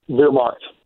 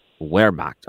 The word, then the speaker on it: Wehrmacht: Is it the Wehrmacht, or how do you pronounce that properly? – Wehrmacht. – Wehrmacht. Okay. And that's Wehrmacht.